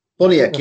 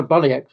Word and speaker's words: Boniek: Boniek, yeah. – How you pronounce? I know Boniek was right.